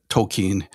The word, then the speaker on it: Tolkien: How do you say Tolkien or Tolkien? I'm always wondering how to, the best way to pronounce it. There's a bit of debate about it, and Tolkien himself talks about this, but Tolkien is good.